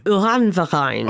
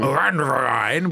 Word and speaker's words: Uranverein: How do I say that? – Uranverein. – Uranverein, which means nuclear club.